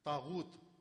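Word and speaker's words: طاغوت: ایک مجلس میں تاغوت, 10 دن تاغوت پر مجلس پڑی, تاغوت غین کے ساتھ.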